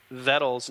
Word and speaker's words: Vettel: And she informed us that Sebastian's last name is pronounced Sebastian Vettel, because in Germany, the V's are pronounced with F's.